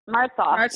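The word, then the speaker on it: Martsolf: Yeah, I guess the big thing on that show was you and Brady, Eric Matsoff. – Martsoff. Yeah, sorry. – Everyone mispronounces his name.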